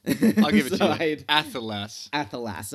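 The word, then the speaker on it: athelas: Yeah, he pronounces it slightly more with an E, rather than an A, so it's like, it sounds like he's saying, like, Ephelas, but yeah, Ephelas.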